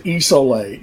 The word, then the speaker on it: Isole: It's we pronounce it Isole.